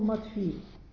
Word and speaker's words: Матфий: И, помолившись Богу, апостолы бросили жребий, и жребий выпал Матфий. И он стал полноправным апостолом. Родился он, тот был Матфей, а это Матфий, пишется по-другому.